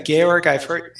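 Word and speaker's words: Georg Eifert: So let's get straight into things here, John, talking about this book that you've wrote in partnership with Georg Effort. Is that the correct way to say his name? – Yeah, Georg Effort, yep.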